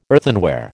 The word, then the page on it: earthenware: American English